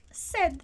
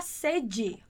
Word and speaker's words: sed: E daí nós temos sed. Cuidado para não falar sede, ok? Que você está com sede e precisa tomar água. Então, sed, sed, sed, ok? Muito cuidado com essa pronúncia.